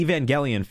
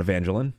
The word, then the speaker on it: Evangelion: But the Evangelion film, which I found out is that's apparently how it's pronounced, and it's not Evangelion, like I've been saying for weeks, but, you know.